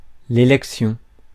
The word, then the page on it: élection: [e.lɛk.sjɔ̃]